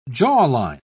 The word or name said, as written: jawline